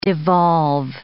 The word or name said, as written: devolve